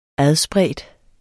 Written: adspredt

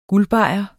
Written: guldbajer

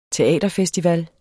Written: teaterfestival

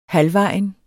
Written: halvvejen

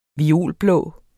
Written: violblå

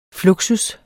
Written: fluxus

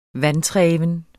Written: vantreven

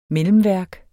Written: mellemværk

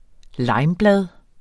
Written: limeblad

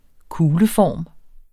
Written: kugleform

